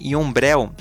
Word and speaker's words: Yumbrel: E o mais legal é que esse nome nome Yombrel, ela se escreve Y-U-M-B-R-E-L, ela é uma palavra da língua indígena Mapuche e ela significa o arco-íris em todo o seu esplendor.